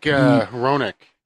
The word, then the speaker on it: Hronek: Yeah, it's like Ronick. H-R-O-N-E-K, but the H is sometimes pronounced as silent.